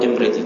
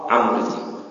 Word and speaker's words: Amriti: Memang ada ulama yang nyebut imriti tapi dhaif. Yang afsah amriti. Nah, kalau kayak Ashmuni khotob, ya, dan itu enggak ada khilaf. Ashmuni, ya, kalau amriti atau imriti, memang saya tahu ada ulama yang membacanya dengan imriti.